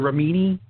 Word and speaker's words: Remini: Still trying to figure out Leah Remini. Is that how you pronounce her last name? – That's how I pronounce her last name.